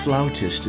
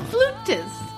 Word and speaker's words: flautist: Dr. David is a composer here in town, and Holly is a professional flautist and singer. – Whoa. Flautist? She doesn't play the flout. She plays the flute. She's a flutist. – I worry about your cultural upbringing. It's pronounced flautist.